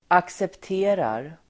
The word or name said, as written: accepterar